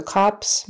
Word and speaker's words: cops: So cops meaning the police, short O, ah, ah.